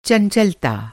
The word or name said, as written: चंचलता